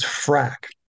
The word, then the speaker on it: Frak: I'm going to spell it first. So FRAK. It was frack.